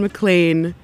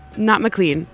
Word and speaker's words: McLean: And she told me that Don McLean is how you pronounce his name. Not McLean, even though that's how it's spelled.